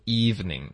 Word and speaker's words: evening: whole special evening, 那 evening 的这个发音大家注意这个 v 的音不要发成 u, 不要双唇都工作 ,ok, 我们只要下唇贴上齿就够了，贴上齿就够了 ,eve, 你要看到 eve, 上唇不要用上唇 ,ok, evening, 如果你用双唇你发出 evening, 对方可能就听不懂了。